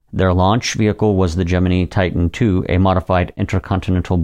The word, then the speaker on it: Gemini: The official pronunciation, as stated by NASA in 1965, was Gemini, not Gemini.